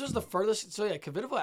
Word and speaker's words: Kvitova: And she's so tall that even, I mean, Kvitova, how do you say her? – Kvitova.